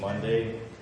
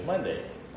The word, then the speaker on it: Monday: I warned her that when you say your last name, say she's giving her last name somewhere and says Megan Monday, that she would have to say and follow it every time with just like the day of the week.